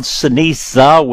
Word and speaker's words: ceniza: It's actually usually called ceniza with an A, Spanish word for ashes, because the original form, always had the gray leaves.